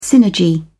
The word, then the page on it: synergy: British English